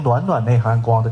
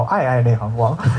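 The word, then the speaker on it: 暧暧内含光: 有一个叫容易被念成“ 暖暖内含光” 的电影，叫《爱爱内含光》。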